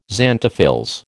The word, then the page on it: xanthophylls: American English